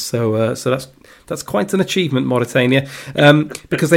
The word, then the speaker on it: Mauritania: Mauritania. – Mauritania.